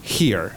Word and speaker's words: here: Aquí se va a decir here. Se escribe H-E-R-E y es un here con una... Es un, esa H en inglés es una J aspirada nuestra.